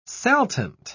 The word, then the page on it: saltant: British English